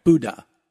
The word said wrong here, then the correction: Boudin: I wasn't even close. – Boudin. – Okay. Do you know how to spell it? – B-O-U.